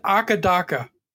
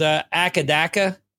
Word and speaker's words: AC/DC: In Australia, on the Conan O'Brien special that they had, they said that they pronounce it Akadaka.